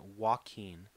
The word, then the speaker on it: Joaquin: No, you're going to say his name right. Joaquin.